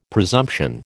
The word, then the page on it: presumption: American English